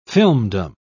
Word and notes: filmdom: British English

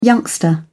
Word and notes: youngster: English; British English

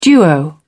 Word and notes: duo: British English

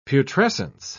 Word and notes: putrescence: British English

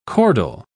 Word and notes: chordal: British English

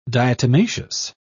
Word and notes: diatomaceous: British English

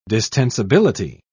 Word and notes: distensibility: British English

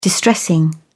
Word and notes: distressing: British English

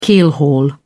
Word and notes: keelhaul: British English

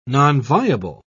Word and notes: nonviable: British English

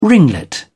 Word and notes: ringlet: British English

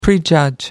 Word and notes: prejudge: American English

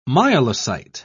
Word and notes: myelocyte: British English

